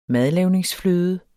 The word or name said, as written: madlavningsfløde